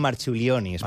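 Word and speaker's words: Marčiulionis: En tot cas, l'escena on Nelson i Marciulionis, no sé si ho he acabat de dir bé. – Jo crec que li diuen Marciulionis, però... Marciulionis. Perquè que aquesta C es pronuncia com una TX.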